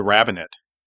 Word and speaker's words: rabbinate: is getting the rabbinate involved. And is it the rabbit? Am I pronouncing that rabbinate correct? – Yes. – Okay, because it's rabbit. It sounds like rabbit.